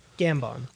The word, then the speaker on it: Gambon: I believe it's spelled G-A-M-B-O-N. I believe it's pronounced Gambon. – Gambon. – Gambon? – Gambon. – So it's a question. – Gambon rhymes with tampon.